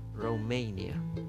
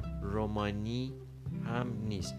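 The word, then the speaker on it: Romania: پس رومانیا نیست رومانی هم نیست در, در زبان انگلیسی ممکنه خود من زبان رومانیایی آشنایی ندارم ممکنه خود اونا بگن رومانی من نمیدونم ولی من فقط میخوام تلفظ صحیح انگلیسی این کشور رو نشون بدم پس رومانیا پنسیلوانیا ما میگیم رومانی و پنسیلوانیا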